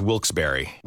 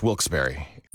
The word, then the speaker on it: Wilkes-Barre: Now, I had heard that it was supposed to be pronounced Wilkes-Barre. Wilkes-Barre Scranton's 97.9X. The only way to pronounce Wilkes-Barre.